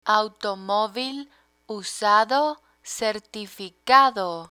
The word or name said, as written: automovil usado certificado